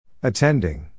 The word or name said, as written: attending